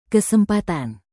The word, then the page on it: kesempatan: Indonesian